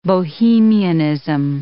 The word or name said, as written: bohemianism